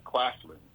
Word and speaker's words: Claflin: Claflin. It's spelled Claflin. Claflin University out of South Carolina.